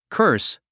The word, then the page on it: curse: kə’ː r s; カァース